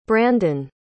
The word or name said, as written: brandyn